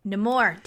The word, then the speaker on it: Namor: Well, they changed it the movie. So it doesn't help you at all. Yeah. I think in the comics, people can pronounce it Namor. But in the movie, they gave it the Namor to make it Spanish. – I need to remember Amor.